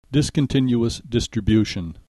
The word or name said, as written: discontinuous distribution